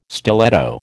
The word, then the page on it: stiletto: American English